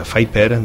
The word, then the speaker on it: Viper: Es diu el projecte, es diu Viper, es pronuncia Viper,